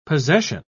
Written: possession

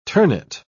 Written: ternate

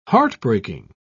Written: heartbreaking

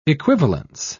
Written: equivalence

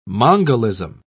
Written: mongolism

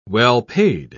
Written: well-paid

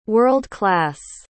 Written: world-class

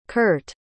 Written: kert

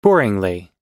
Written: boringly

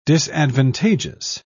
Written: disadvantageous

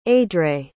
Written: aedre

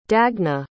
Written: dagna

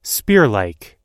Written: spearlike

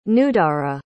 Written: nudara

